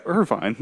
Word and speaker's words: Irvine: Oh yeah, Irvine. That's how you pronounce that word.